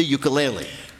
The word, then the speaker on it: ukulele: the ukulele.